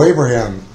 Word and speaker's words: Abraham: Joe Abraham, A-B-R-A-H-A-M.